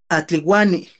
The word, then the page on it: atliuani: Náhuatl